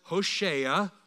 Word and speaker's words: Hosea: It's actually pronounced Hosea.